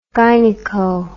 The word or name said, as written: gyneco